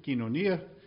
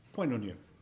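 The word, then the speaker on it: kinonia (κοινωνία): In modern Greek pronunciation, kinonia, very popular when I'm a child, but mispronounced seminary fashion, poinonia.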